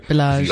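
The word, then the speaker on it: balayage: It's not ombre, it's Balage. I hope I'm saying that right. – Bellage. – You guys always get mad at me when I say it wrong.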